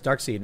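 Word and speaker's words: Darkseid: I know, and I called him Dark Side, and you were like, no, I'm pretty sure it's Dark Seed.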